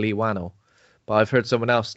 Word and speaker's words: Whannell: which is a James Wan and Lee Whannell, or Lee Whannell, if we're committing to the Horror Hangout pronunciation.